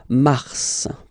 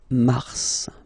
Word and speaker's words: mars: So in a way, it's an exception because normally final S is not pronounced in French, okay? We've got some exceptions, and this one is one of them. So mars, pronounce the final S, mars, mars, okay?